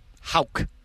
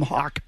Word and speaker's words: Houck: Houck. Like, okay. Ralph Houck, the old manager. – I want to know it to mispronounce it. Know it before I mispronounce it. – You can call him Hawk. It's fine.